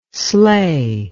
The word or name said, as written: sley